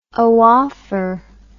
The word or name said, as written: oophor